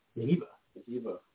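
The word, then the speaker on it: Njigba: How do you say that last name? Najiba? – Najiba.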